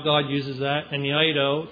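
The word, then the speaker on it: eido (εἴδω): Hooper is a word that means above, beyond, and eido, it's spelled like E-I-D-O, eido is a word that means know, or look.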